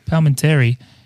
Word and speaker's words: Palminteri: written by Chaz Palminteri. Is that how you say it? – Yeah, Palminteri.